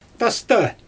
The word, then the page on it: tasta: Xyzyl